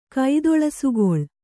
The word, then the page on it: ಕೈದೊಳಸುಗೊಳ್: kannada